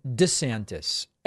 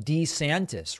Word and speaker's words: DeSantis: He's demanding that people call him DeSantis rather than DeSantis. Actually, I like Da better, a nicer flow, so I'm happy he's changing it. He gets very upset when people, including reporters, don't pronounce it correctly.